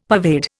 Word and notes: pervade: Indian

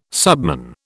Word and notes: subman: British English

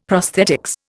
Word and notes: prosthetics: Indian

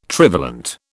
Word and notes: trivalent: British English

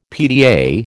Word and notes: PTA: American English

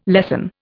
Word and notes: lessen: Indian

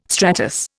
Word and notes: stratus: Indian

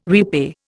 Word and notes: repay: Indian

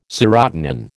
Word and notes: serotonin: American English

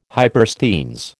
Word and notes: hypersthenes: American English

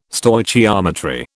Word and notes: Stoichiometry: British English

